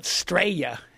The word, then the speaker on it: Straya: They call it Straya. Straya. Straya. – That's how they say it.